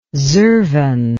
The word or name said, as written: zervan